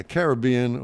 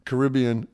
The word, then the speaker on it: Caribbean: a Caribbean or Caribbean. What is it? Caribbean or Caribbean? They can pronounce it either way.